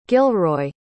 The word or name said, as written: gilroy